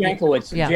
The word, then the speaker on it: Jankowicz: I think it's Jankowicz.